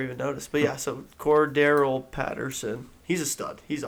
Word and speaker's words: Cordarrelle: Cordell Patterson. Did you guys see we're saying his name wrong? – What is it? – Cordarrelle Patterson. – That's what we have been saying. – Yeah, that's we've been saying. – Well, like Cordell. Name. I always say Cordell. – Yeah, you've been saying. – Yeah, you've been saying it wrong.